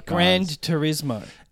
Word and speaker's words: Gran Turismo: Yeah, it's like Gran Turismo.